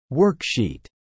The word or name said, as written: worksheet